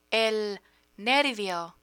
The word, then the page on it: nervio: spanish